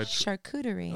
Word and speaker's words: charcuterie: We do need Shakura boards. Let us get some Shakura boards. – I want you to say it correctly so bad. – I can't. Even if I charcuterie.